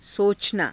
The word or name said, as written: socanaa